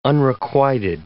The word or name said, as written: unrequited